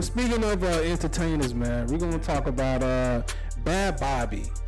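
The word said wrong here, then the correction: Bhad Bhabie: Am I saying her name right? – Bad Baby. – Bad Baby. Yo, her name is so weird. It's the H's that throw me off.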